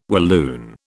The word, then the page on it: Walloon: British English